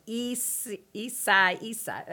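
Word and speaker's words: Isaias: Mike's weather page is pronounced Isai Isai. That's what it's Isai Isai.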